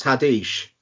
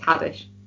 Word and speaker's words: Haddish: You're the only person I think I've ever heard it pronounced Haddish. – Yeah, it's Haddish.